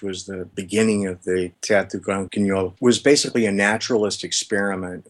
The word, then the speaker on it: Grand Guignol: I'd love to talk more about art and creative endeavors, maybe through talking about your article about the Théâtre du Grand Guignol. Is that how you pronounce it? – Uh-huh, yeah.